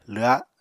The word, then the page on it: lwha: apatani